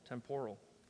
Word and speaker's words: temporal: Those things are all temporal. Temporal. Temporal? There we go. Put the accent on the right syllable.